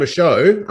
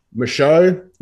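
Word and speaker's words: Michôd: Like, David Michaud, I'm going to put it out there now so I can pronounce it properly. – Yes. – Michaud, not Michaud.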